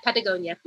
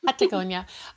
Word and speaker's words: Patagonia: So you said you were living in Patagonia. I don't want to say it wrong. Is that right? – Yeah, Patagonia. – Patagonia.